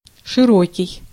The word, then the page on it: широкий: [ʂɨˈro̞kʲɪj]; [ʂɨˈrokʲɪj]